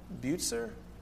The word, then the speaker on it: Bucer: So Zwingli, so now third, lastly for the Reformation then, we have Calvin and I think it's pronounced Bucer, B-U-C-E-R.